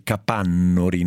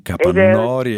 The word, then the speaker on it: Capannori: Si dice capannori, non capannori.